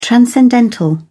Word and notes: transcendental: British English

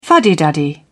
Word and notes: fuddy-duddy: British English

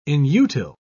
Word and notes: inutile: British English

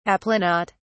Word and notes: aplanat: British English

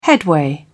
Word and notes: headway: British English

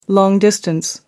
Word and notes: long distance: American English

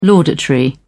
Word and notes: laudatory: British English

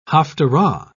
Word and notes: haphtarah: British English

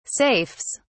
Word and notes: safes: British English

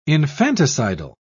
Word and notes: infanticidal: British English

